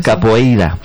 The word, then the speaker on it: Capoeira: Pues todos sabemos lo que es la copaíra, ¿verdad? Capoeira. Qué mal pronuncio últimamente.